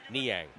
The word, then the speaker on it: Niang: Niang, how do you pronounce that name? Niang? – Niang.